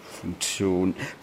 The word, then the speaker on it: Funktion: Ist doch kein Z. – T-I wird auch Z ausgesprochen. – Also heiße ich Zibor? – Funktio. – Funktion.